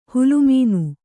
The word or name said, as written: ಹೊಲೆಮನೆ